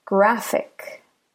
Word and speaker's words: graphic: All right, so here there are only two syllables. Graph, ick. Graph, ick. Graph, ick. So the second to last syllable for graphic is actually the first syllable. So graph is stressed.